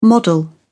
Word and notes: model: British English

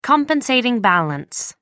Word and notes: compensating balance: American English

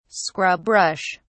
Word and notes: scrub brush: British English

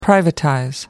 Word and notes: privatize: American English

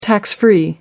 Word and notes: tax-free: American English

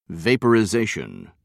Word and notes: vaporization: British English

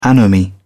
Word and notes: anomie: British English

